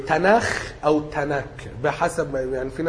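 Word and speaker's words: تناخ (תנ״ך): ماشي، يعني العهد القديم كتب اليهود في مسمى اليهود اسمه تناخ.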